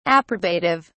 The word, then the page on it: approbative: British English